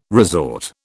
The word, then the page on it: resort: British English